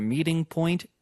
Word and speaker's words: meeting: 역시나 중간에 트 발음은 R로 좀 약화가 되면서 원래 이제 meeting point가 meeting point로 들렸고요.